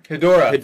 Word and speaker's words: Ghidorah: Ghidorah. Thank you for saying it right.